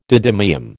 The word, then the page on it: didymium: American English